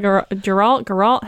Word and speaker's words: Geralt: Someone draw Geralt.